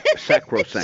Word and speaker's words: sacrosanct: Which you would think that would be sanctosanct. No. Now what we're finding out, I know. I said that wrong. – Sanctosanct? – Sacrosanct.